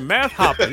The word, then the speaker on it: Math Hoffa: Math Hoffa, you got it right.